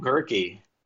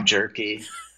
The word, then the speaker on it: Gehrke: Just like it sounds. – How do people pronounce it? You say they pronounce it wrong. What do they say? – Tom Jerky, which is probably true on occasion. It's really harsh around Thanksgiving with Tom Turkey, which rhymes.